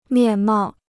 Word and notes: 面貌: miàn mào

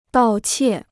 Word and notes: 盗窃: dào qiè

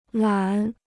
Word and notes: 懒: lǎn

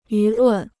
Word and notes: 舆论: yú lùn

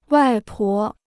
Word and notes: 外婆: wài pó

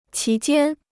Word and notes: 其间: qí jiān

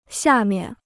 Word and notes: 下面: xià miàn

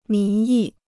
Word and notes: 民意: mín yì

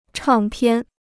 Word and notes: 唱片: chàng piàn

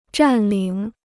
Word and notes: 占领: zhàn lǐng

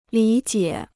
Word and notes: 理解: lǐ jiě

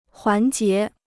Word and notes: 环节: huán jié

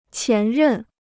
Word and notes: 前任: qián rèn

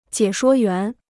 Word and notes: 解说员: jiě shuō yuán

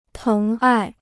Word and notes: 疼爱: téng ài

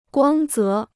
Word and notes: 光泽: guāng zé

